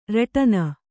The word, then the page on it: ರಟನ: Kannada